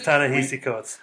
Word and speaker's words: Ta-Nehisi Coates: It is Ta-Nehisi Coates. That one I do know.